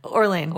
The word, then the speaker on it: Orlean: Does she pronounce her last name Orlean or Orlean? – Orlean.